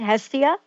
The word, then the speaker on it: Hestia: One was Hestia. Is that right, Hestia? – Yeah, and the other one, G-A-E-A.